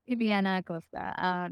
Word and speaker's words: Illianna Acosta: Illianna Acosta.